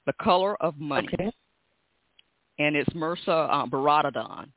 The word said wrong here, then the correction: Baradaran: Baradaran is her name, Baradaran.